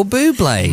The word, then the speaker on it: Bublé: His first name is Michael, and his surname rhymes with Kublai. – Interesting. Who are you going to be tonight? – Tonight, Matthew, I'm going to be Michael Bublé.